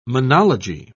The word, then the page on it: monology: British English